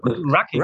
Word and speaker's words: Rucking: und zwar was ist Rocking, wenn ich es richtig Rucking. ausspreche? – Rucking.